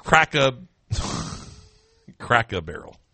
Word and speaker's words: Cracker Barrel: It's pronounced crack a barrel.